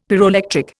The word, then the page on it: pyroelectric: Indian